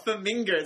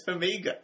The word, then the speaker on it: Farmiga: You put N's everywhere. It's not Farmiga, it's Farmiga.